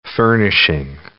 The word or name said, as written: furnishing